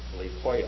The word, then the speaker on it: huios: I'll spell it for you, H-U-I-O-S. Leipoios is the way we say it.